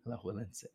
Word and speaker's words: Alajuelense: In the round of 16, LAFC played Alajuelense? Alajuelense? – Yeah, that's the one that I didn't want to pronounce, but I believe that's correct. – Wow, okay, go me. – Alajuelense.